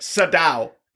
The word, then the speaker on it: Sydow: No. Read it. Sydow. Max von Sydow. – Sydow. Sydow. Okay. – If I'm wrong, I don't know, but there's nothing telling me that that should not be pronounced.